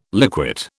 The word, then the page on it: liquate: British English